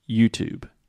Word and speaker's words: YouTube: So the last sound is the B sound. There is no E at the end. In writing, there is an E, but in speaking, the E is silent. YouTube.